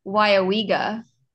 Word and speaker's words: Weyauwega: It's pronounced Weyauwega.